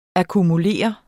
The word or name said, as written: akkumulere